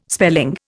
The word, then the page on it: spelling: Indian